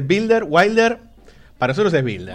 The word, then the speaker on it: Wilder: Así que podemos decir Billy Builder, ¿sí? – Asumimos que la W se debería pronunciar como B, pero bueno, es tradición y una en Argentina que le digan Wilder.